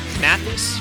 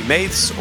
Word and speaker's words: Mathes: Another Steve Mathis. Can I say it like that? – Yeah, you actually got it right. You didn't call me Maths or Stephanie, so I really do appreciate that.